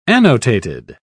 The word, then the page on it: annotated: British English